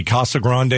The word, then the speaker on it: Casa Grande: Casa Grande Elementary Schools have moved to four-day school weeks.